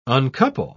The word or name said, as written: uncouple